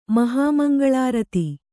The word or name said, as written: ಮಹಾಮಂಗಳಾರತಿ